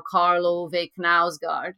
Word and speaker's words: Karl Ove Knausgård: So without Meg, you know, it probably would have been like as long as, you know, Karl Ove Knausgård, you know.